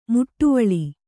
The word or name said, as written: ಮಿಡಿವಿಲ್